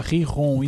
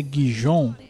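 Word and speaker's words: Gijón: Depois que Giron se pronuncia Riron e não é Gijon, eu, eu desisto, mano.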